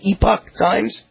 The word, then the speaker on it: Epoch Times: I see a headline right here in, I think you pronounce it Epoch Times. Do you know that's the way they pronounce it, Epoch Times? – I think it is, yeah.